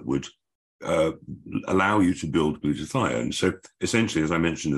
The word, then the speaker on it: glutathione: Glutathione, okay.